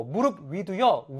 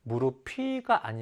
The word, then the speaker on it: 무릎 위: 자, 마찬가지로 무릎 위도요, 위. 뜻을 지녔잖아요. 따라서 르 밑에 피읍을 비읍으로 바꾸어서 무릎위라고 읽어주는 겁니다. 무릎피가 아니고요.